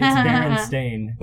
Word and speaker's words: Berenstain: It is spelled with an A instead of an E. It's Berenstain.